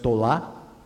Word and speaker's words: tola: The male is pronounced tola, and the female is tolat.